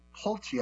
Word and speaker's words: Pulte: The announcement comes amid furious backlash toward interim DNI Bill Pulte, I believe is how it's pronounced, P-U-L-T-E, who lacks any intelligence experience and comes from the housing sector.